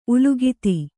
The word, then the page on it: ಉಲ್ಲಿಖಿತ: ullikhita